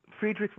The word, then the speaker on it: Friedrichs: And when we talk about public employee unions, there's a case, how do you pronounce it, the Friedrichs case? – Friedrichs versus California Teachers Association will be right.